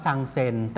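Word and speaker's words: ซัง เ ซ น (三千): ซังเซน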